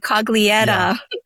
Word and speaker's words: Coglietta: My husband's Italian, Coglietta. – Yeah. Coglietta. – You got to make the fingers like this. That's why I went. – Coglietta.